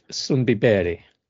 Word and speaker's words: Sundbyberg: Because I think the listeners will want to see the salads that you are pumping out to the residents of Sundbyberg. – Very well pronounced there.